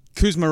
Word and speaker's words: Kuzma: Yeah, if I'm on, if I'm on, if I'm one of his teammates, I mean, Kuzma rhymes. Kuz rhymes with lose.